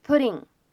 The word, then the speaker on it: putting: Maybe I'm maybe I'm just putting myself in your place。在这段话当中呢，我们需要注意一下这样的啊两处发音技巧。首先，putting 这样一个单词当中，这个 double t 呢，它在美式发音当中是一个 flap t，是一个闪音的处理。我们呢应该读成 pudding，pudding。那么前面呢加上一个 just，那么这样子的话呢，又形成了一个失去爆破的现象。所以呢，我们可以读成 just pudding，just pudding，just pudding。